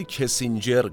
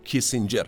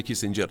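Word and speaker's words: Kissinger: نیویورک ایالات متحده آمریکا دهه هفتاد میلادی جمله ای که از قول کیسینجر در ابتدای این اپیزود گفتم من یه توضیح بدم در ادبیات مخصوصا سیاسی ایران خیلی کیسینجر گفته میشه ولی اسم درست ایشون و تلفظ درست کیسینجر هست به همین جهت اجازه بدید بنده کیسینجر تلفظ کنم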